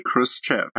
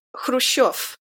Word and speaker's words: Khrushchev: So I don't know why everybody puts a K in his name. It's an H. – Oh. – It's just, like, it's Khrushchev. – Khrushchev. – Yeah. Yeah, so Khrushchev is literally H-R-U-S-H-E-V.